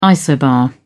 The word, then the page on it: isobar: British English